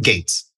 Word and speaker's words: Goetz: It's an article that you recently, well, you wrote with one of your partners, Phyllis Goetz. Is that how you pronounce her name? – Gates.